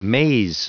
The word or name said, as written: maze